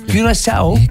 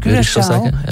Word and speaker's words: Curaçao: Heute Abend um 10 Uhr spielen, Achtung, Aussprache, Curaçao. – Curaçao.